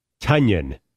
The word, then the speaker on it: Tonyan: Tonyan. – Tonyan. – Rhymes with Funyan.